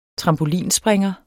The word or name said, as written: trampolinspringer